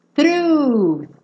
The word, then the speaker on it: through: Through. Não se preocupem com esse GH no final, tá? Ele é mudo.